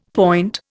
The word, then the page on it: point: Indian